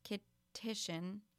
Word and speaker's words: Kittitian: Suddenly, a Kittinian, I think this is pronounced Kittitian, yes, Kittitian passport could be presented to Chinese or Russian businessmen as a must-have.